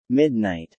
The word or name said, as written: midnight